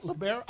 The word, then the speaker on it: libero: I just kind of slide in and so forth because all these people don't even know what the libero is, and that would have to change if you start paying all your athletes. – How do you pronounce that? Libero? – Libero. – Libero. – Libero. L-I-B-E-R-O.